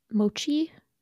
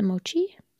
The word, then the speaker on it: mochi: And M said, making a mochi. Is it mochi? Is that how you pronounce that? – Is it M-O-C-H-I? – Yeah. – I think so.